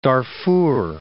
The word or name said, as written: Darfur